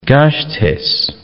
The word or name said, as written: Gasteiz